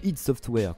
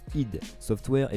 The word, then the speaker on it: id Software: gueule, Développé par le studio id Software, qu'on prononce bien id Software et pas ID Software, même s'il y a de l'idée.